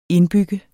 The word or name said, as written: indbygger